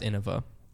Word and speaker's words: Innova: Hey, if you're listening to this and you're new, there's no shame in it, but it's pronounced Innova.